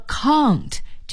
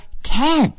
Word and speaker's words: can't: 不是呢，是 count, C-A-N-P-R-T, count。在这里想请朋友们注意，如果您说 count, 这是英音的发音方式，而说 can't, 则是美音的发音方式。